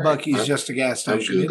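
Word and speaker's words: Buc-ee's: Oh, it's spelled B-U-C-E-E. – Bucky's.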